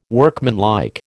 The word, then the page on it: workmanlike: American English